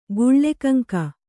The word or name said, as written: ಗುಳ್ಳೆಕಂಕ